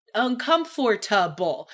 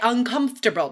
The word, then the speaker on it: uncomfortable: And I want to make a quick pronunciation note here, guys, because I still hear students say uncomfortable, like saying every single every single sound. – Syllable, yeah. – We don't say that, guys. We say uncomfortable, not uncomfortable.